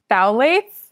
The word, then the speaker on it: phthalates: It's phthalates. – Phthalates. – But it's P-H-T-H-A-L-A-T-E-S.